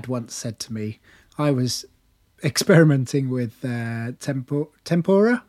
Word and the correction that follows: tempera: Am I saying that right? – Tempera. – Tempera. Tempura is the deep fried vegetables, isn't it? – Yep.